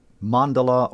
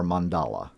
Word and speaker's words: mandala: Soul symbol mandala or mandala? – That's a good question. I hear that often, actually. It's pronounced both ways. I've heard the Tibetans say mandala and others mandala, so either one is good.